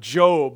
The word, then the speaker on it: Job: And I actually thought people were mispronouncing when they called it Job, but it's actually the book of Job.